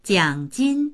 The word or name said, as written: jiang3jin1